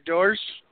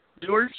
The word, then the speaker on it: Doers: did I pronounce the last name right before? Is it Doers? Doers? – Yes.